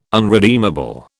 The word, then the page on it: unredeemable: British English